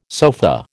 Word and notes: sofa: American English

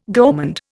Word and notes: gourmand: Indian

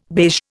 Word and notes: beige: Indian

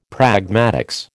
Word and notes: pragmatics: American English

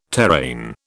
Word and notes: terrain: British English

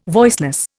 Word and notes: voiceless: Indian

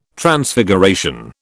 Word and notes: Transfiguration: British English

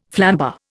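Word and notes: flambé: Indian

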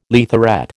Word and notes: leatherette: American English

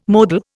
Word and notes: modal: Indian

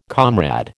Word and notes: comrade: American English

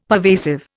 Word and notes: pervasive: Indian

